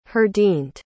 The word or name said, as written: heardind